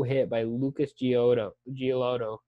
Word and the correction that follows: Giolito: Giolito. Lucas Giolito.